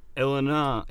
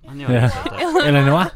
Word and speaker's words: Illinois: And on the 24th, a small group of people gathered outside Dorothy's house, which is in Illinois, that's how you say it. – Illinois.